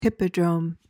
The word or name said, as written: hippodrome